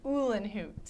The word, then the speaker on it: Ullerhuth: I trashed your last name. – It's all right. It's Ullerhuth. – Ullerhuth. – Yeah. – Okay. – But nice and foreign and hard to pronounce.